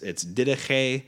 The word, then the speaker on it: Didache: It's the way you would pronounce the book, but it's Didache, but it's spelled Didache.